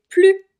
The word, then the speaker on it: plus: Ici, on ne va pas prononcer le S, on va dire « c'est plus difficile que je le pensais ». C'est plus difficile que je le pensais, parce que le mot qui suit commence par une consonne.